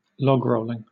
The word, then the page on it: logrolling: British English